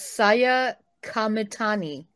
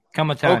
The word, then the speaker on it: Saya Kamitani: Maybe you can help me pronounce this. Number seven. You you, you got it pulled up. – I'm gonna guess Saya Kamitani. – Kamitani. – Okay. – Okay. There you go. There's number seven. Thank you. That sounds about right.